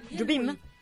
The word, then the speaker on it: Jobim: Sui colori dell'anima abbiamo quello che avevamo appena ascoltato, mi dicono che era Wave di Jobim. Così? Jobim? – È lui! – Ok, avevo paura sulla pronuncia del nome e ormai sono già le 21:53.